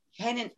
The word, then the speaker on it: Sheinina: Sheinina.